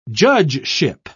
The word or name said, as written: judgeship